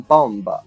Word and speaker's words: bomb: OK，接下来呢，一个单词叫做 bomb，bomb，b o m b，这个 b 啊在这里面不发音，所以啊不要读成 bomb 啊。